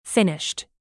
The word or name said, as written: finished